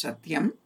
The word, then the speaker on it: Satyam: Another name for Brahman is Satyam. This word Satyam has got three syllables, that is Sa, Ut, and Yam.